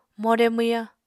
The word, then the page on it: more mwyañ: Apatani